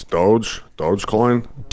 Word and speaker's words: Dogecoin: Dodge. Am I saying that right? Dodge? Is it Dodge? – No, it's Dogecoin.